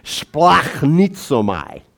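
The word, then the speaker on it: splachnitsomai (σπλαγχνίζομαι): It's splachnitsomai. It's actually how you pronounce it.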